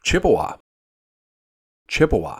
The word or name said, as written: Chippewa